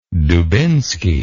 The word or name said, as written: dubinsky